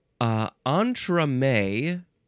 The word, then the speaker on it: entremets: Entremets, or I guess the plural would be entremets, but it is spelled E-N-T-R-E-M-E-T-S, which looks plural. It says when it is singular, it's entremets. When it's plural, it's entremets.